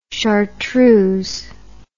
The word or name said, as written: chartreux